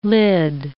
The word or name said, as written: lid